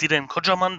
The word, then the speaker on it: Diren Kocaman: Also, mein Name ist Diden Kodjaman. Das war schon sehr gut ausgesprochen. Passiert nicht sehr häufig.